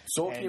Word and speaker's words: Tzolkin: Zolkin, and, by the way, is spelled TZOLKIN.